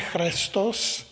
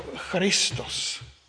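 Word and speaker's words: Christos (Χριστός): to je jako v řečtině chrestos, a už tehdy se to písmeno eta, to chre, četlo nebo vyslovovalo, psalo se to chrestos, ale vyslovovalo se to jako i, jako Christos.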